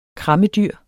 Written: krammedyr

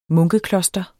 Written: munkekloster

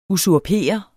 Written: usurper

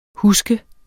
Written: huske

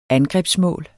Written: angrebsmål

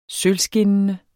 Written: sølvskinnende

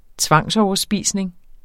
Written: tvangsoverspisning

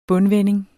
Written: bundvending

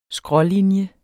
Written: skrålinje

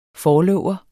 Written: forlover

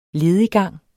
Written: lediggang

